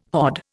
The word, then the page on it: hod: Indian